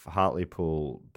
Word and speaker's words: Hartlepool: What's it called? Hartley Pool? – Hartlepool. – That's how you say it. I got in trouble for saying Hartlepool because it's Hartlepool.